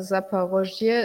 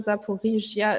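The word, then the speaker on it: Saporischja (Запоріжжя): Also Saporoschje ist das Russische, Saporischja ist ukrainisch.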